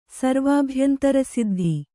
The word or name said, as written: ಸರ್ವಾಭ್ಯಂತರಸಿದ್ಧಿ